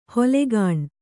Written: ಹುಲುಗಾಣ್

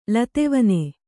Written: ಲತೆವನೆ

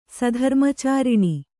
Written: ಸಧರ್ಮಚಾರಿಣಿ

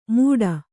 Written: ಮೂಢ

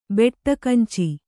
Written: ಬೆಟ್ಟಕಂಚಿ

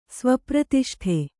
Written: ಸ್ವಪ್ರತಿಷ್ಠೆ

